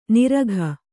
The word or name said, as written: ನಿರಖ್